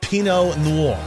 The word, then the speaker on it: Pinot Noir: Pinot Noir.